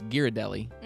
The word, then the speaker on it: Ghirardelli: Up until the other night, I didn't realize what the correct way to pronounce that brand is. It's Ghirardelli.